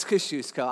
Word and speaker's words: Kosciuszko: And as I go over the Kosciuszko, however you pronounce it, bridge on the BQE, okay, it's Kosciuszko, I know, but I like to say it that way, relax.